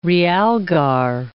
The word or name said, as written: realgar